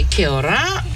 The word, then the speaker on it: kia ora: You should say kia ora.